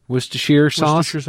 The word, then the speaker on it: Worcestershire: Worcestershire sauce.